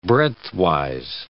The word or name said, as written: breadthwise